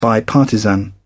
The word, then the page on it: bipartisan: British English